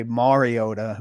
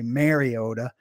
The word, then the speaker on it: Mariota: In Nashville, on my radio show, the Midday 180 in particular, we just crush people who don't say Mariota, who say Mariota.